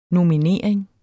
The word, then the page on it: nominering: nomiˈneˀɐ̯eŋ